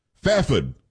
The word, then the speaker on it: Fairford: Fafid is what I was told how I should pronounce it from Kay.